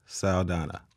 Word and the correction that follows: Soldadua: Close. – Saldua. – It's Neska Soldadua, which is the girl soldier.